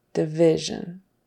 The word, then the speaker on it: division: it should be an I, which is what we would call a short I sound. So I instead of E. So prism and division, division, not division. Division. Prism, not prism.